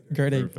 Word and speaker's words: Gerdegotit: How would you pronounce it? – Gerdegotit. – Yeah. – Gerdegotit. – Perfect.